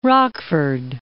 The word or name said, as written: Rockford